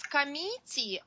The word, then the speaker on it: committee: There is no meat in this word. So neither of your pronunciations was correct. But the second syllable is what we want to committee. Committee. – Committee. – Mit, but not meat. Not committee, but committee. Committee. – Committee. – Committee. – Committee. Committee. A short sound.